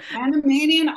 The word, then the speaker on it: Panamanian: Go and let us know what's happening in the Panamanian, how do you say it now? Panamanian? – Panamanian aquaculture scene.